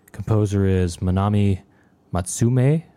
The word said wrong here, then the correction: Matsumae: or I don't know, maybe lately it's been brought to my attention that it may actually be pronounced Matsumae.